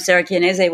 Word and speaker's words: Sarah Chianese: Uh, yes, I am Sarah Chianese with Mangia and Enjoy is the name of our company.